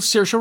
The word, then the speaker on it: Saoirse: It's Saoirse with an er, not Saoirse with an or, because Saoirse with an or goes or, not er. It's sh instead of... – That was the wonderful Saoirse Ronan, also on Saturday Night Live, doing a takeoff of Liza with a Z to explain how to pronounce Saoirse.